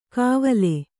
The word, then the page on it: ಕಾವಲೆ: Kannada